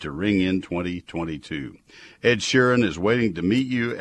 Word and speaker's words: Sheeran: Uh, from January uh, 2, from in other words, from right now through the 16th, Ed Sharan and all, uh, if I'm pronouncing that correctly, and all Sharon, thank you, Mike. Mike stood all he could. Man, he sounded insistent in my headphones. All right, Ed Sheeran and all the other adult dogs and cats.